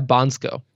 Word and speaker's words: Bansko: Am I pronouncing that correctly? – Yeah, Bansko.